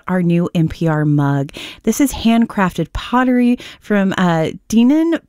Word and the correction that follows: Deneen: Pottery. Am I saying that right, Zach? – It's Deneen, I believe.